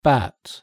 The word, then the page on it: bat: British English